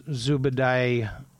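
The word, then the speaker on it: Zubaydah: Yeah, and when you hear about uh, detainees, uh, Zubedai, and I, I believe that's how his name is correctly pronounced. By the way, I think I've called him Zubedai, but anyway, Zubedai,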